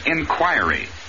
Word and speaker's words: inquiry: And the word here is inquiry. Accent always on the second syllable, and the I is always long.